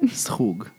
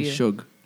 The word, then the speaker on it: schug: That's how people say it? Or they say like shug? – They all say shug. – Yeah. – And it's hard. At first, I was like, that's not how you say it.